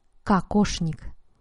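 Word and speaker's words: kakoshnik (кокошник): Se escribe en ruso kokoshnik, pero se pronuncia kakoshnik.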